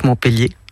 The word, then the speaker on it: Montpellier: Je suis née à Montpellier, que je prononce Montpellier, hein, à l'occitane.